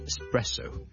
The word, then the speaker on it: espresso: 即特別地咧，尤其是咧，especially 第二個字咧，都係 es 開頭嘅就係 espresso， espresso， espresso， espresso，即係意式嘅特濃咖啡。我哋呢兩個字嘅 es 咧，我哋都唔好將佢讀成 x，唔好 espresso，唔好 especially， but espresso， e 或者 e 都得。直接加个 s，中间唔好加咗个 k 音，